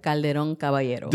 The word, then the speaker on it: Calderon-Caballero: Maritza Caballero Calderon, or did I say it right? – Calderon-Caballero. – See, I messed it up already.